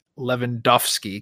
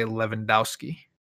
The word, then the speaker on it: Lewandowski: people who say Lewandowski. – I mean, he is Lewandowski, so... – Wait, people say Dowski? – No, I say Lewandowski. – Lewandowski, yeah. Like the W's an F, yeah.